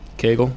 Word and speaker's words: Kagel: And for those who am I pronouncing it properly? – K A G E L. – I care. – K A G E L. – Did I pronounce it properly? – Did you say Cagle? – I did. – Well, by Joe, you got it right.